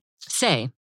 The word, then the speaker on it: Saie: S-A-I-E? – Say. – Oh, it's just Say.